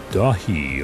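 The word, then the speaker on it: Daithi: From Elion called Dahi, although it's spelled D-A-I-T-H-I, I'm pretty sure it's in Gaelic.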